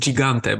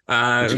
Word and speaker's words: Gigante: Anyway, I consulted with an Italian friend and apparently it should be pronounced Gigante, by the way. – Gigante.